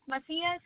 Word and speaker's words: Macias: E-L-E-N-A? – Can I get your last name, please? – Macias, M-A-C-I-A-S.